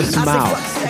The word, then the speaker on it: Simão: Zhao Simão. It's really pretty easy. It's pretty easy. – Simão. – It rhymes with his first name.